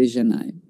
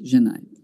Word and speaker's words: Xenai: So I made a Xenai. It sounds complicated, but it's actually just like, you know, when you say bonjour, you have a soft J. It's basically that, Xenai.